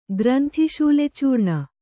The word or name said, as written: ಗ್ರಂಥಿಶೂಲೆಚೂರ್ಣ